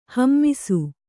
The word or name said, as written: ಹೇಮಿಸು